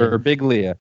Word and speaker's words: Birbiglia: Birbiglia.